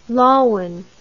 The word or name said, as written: lawine